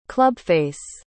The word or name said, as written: clubface